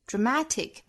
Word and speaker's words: dramatic: Dramatic 尾部的 k 的发音别掉 dramatic changes 白口型，但是不爆破。